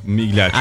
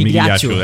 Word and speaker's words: Migliaccio: Migliaccio, acho que, é mesmo. Migliaccio, é, acho que assim é assim a, a, pronúncia.